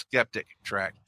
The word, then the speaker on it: Skeptic Track: And just like podcasting came out of Skeptitrack, I can see, I always say that wrong. I always say Skeptitrack instead of Skeptic Track.